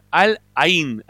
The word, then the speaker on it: Al Ain: de los últimos cinco partidos perdió dos, empató uno, este, y ahí es donde queda fuera contra el Al Anin, Al no, Al Ain, ahí lo dije bien.